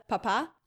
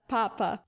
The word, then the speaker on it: Papa: Da muss man auch auf die Betonung ganz genau achten, weil der Vater ist der Papa und der Papst wäre der Papa. Genau, geschrieben werden sie aber bis auf den Akzent genau gleich.